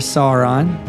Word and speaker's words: Sauron: There's the Eye of Sauron.